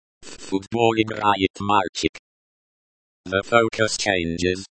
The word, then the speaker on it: мальчик: The stress in мальчик is on the first syllable.